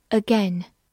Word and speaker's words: again: again，again，或者读作 again，副词又一次，再一次。